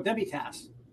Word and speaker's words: Demitasse: Correct me if I say this wrong as well, Richard. Demitasse? – Oh, Demitasse.